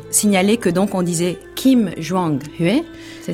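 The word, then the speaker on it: Kim Jong-hui (김정희): Nous sommes aujourd'hui en compagnie de Christine Jordis, qui va nous emmener en Corée sur les traces de Kim Jong-hui. Je ne sais pas si je prononce bien. – Très peintre. Bien, c'est ça.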